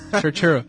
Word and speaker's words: Turturro: Sí, sobre todo teniendo en cuenta que es americano y tiene que pronunciarlo en plan Turturro. Turturro.